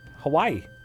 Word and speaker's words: Hawaii: I also like that it's like a sneaky three syllables. – Hawaii.